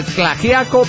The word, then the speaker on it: Tlaxiaco: Esto desde la Mixteca en Oaxaca, en Tlaxiaco, Oaxaca, se pronuncia Tlaxiaco, pirata, y amo para decir al Iron Priest, el mundo no se acaba, güey.